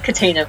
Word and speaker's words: Cataena: Thank you, it's Cataena, but thank you.